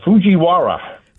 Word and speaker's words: Fujiwara: It's the Fujiwara. – Fujiwara. I got that right? – Fujiwara effect.